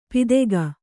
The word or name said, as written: ಪಿದೆಗ